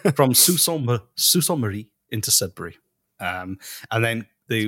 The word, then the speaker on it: Sault Ste. Marie: And then they were going to attack from Buffalo across the Niagara River and from Detroit to Windsor and from, how do you say it? Sault Ste. Marie? – Yeah, actually, that's pretty good.